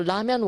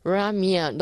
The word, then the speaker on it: ramen: Okay, so ramen was better. 떡볶이보다는 그래도 발음 잘 하셨네요. Ramen, 너무 이렇게 할 필요 없어요. 너무 라면을 굴려서 발음하지 마시고요. Ramen, 이렇게 똑부러지게 발음을 하셔야 됩니다.